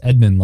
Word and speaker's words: Edmund: Yes, Edmund, like Edmund Dantes or Edmund from Chronicles of Narnia, who betrayed all his friends for Turkish delight.